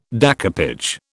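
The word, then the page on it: découpage: British English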